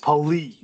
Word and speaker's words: Poley: Poley. – Poley. – I think I mispronounced it, but emphasis on the Lee.